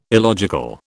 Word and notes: illogical: British English

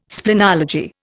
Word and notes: splenology: Indian

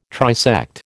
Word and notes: trisect: American English

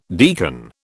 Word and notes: deacon: British English

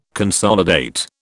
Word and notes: consolidate: British English

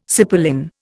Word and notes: cipolin: Indian

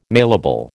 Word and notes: mailable: American English

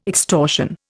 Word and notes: extortion: Indian

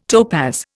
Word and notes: topaz: Indian

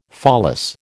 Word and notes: pholas: American English

